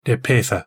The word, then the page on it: de petha: Galindri